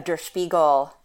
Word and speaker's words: Der Spiegel: Sort of piggybacking on what you said, I also just, or I think yesterday or 2 days ago, retweeted a Der Spiegel. Sorry, Megan, did I pronounce that correctly? – Yeah, you're right.